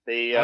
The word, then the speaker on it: Rennes: Rennes was a character in Hello, Hello, the camp 1980s sitcom.